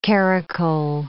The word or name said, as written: caracole